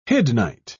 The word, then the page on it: hiddenite: British English